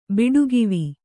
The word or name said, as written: ಬಿಡುಗಿವಿ